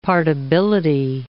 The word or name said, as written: partibility